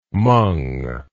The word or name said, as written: mong